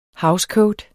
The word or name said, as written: housecoat